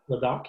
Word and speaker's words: Ladakh: But Ladakh? Did you say Ladakh? – Ladakh. – Ladakh. Yeah, that's correct.